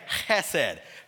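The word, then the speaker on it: chesed (חסד): And chesed, when you pronounce it, actually that H has kind of a guttural sound to it. So it's like chesed.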